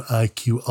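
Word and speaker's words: EyeQ: Its product line is called IQ. It sounds like the letters that stand for intelligent quotient, but it's spelled like the word I, implying a combination of machine vision and artificial intelligence.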